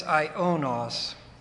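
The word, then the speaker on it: aionos: Whereas the word ion or Ionos, calls attention to, I should make this Ionos, A-I-O, long O, N-O-S, short O.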